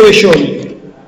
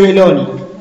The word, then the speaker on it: Belloni: Vamos a ir, como bien decía Federico, con este artista que es José Belloni o José Belloni, cualquiera de las dos formas podemos decir que está bien pronunciado.